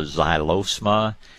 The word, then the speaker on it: xylosma: Then probably my first choice would be something called a xylosma. It's spelled like xylophone. It's spelled X-Y-L-O-S-M-A. Xylosma, oh gosh,